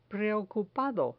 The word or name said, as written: preocupado